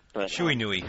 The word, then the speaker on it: Shiranui: Marufuji hit the Shiranui. I don't know how he pronounces it. – Shuinui. That's how I say it.